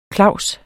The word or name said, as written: klaus